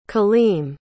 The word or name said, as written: kaleem